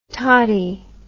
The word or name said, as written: tottie